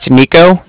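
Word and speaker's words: Niko: It's Nico. That's N-I-K-O. – Okay, and then what is the bank? – Bank of Cardiff. That's spelled C-A-R-D-I-F-F, as in Frank Frank.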